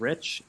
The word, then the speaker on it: Rich: My Twitter handle is at Jacob James Rich, exactly as it's spelled, or sounds. It's spelled exactly as it sounds. Last name is R-I-C-H.